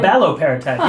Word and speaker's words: abaloparatide: Abaloparatide. – Uh, abaloparatide.